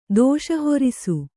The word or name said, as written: ದೋಷಹೊರಿಸು